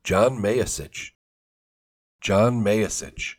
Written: John Mayasich

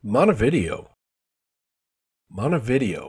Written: Montevideo

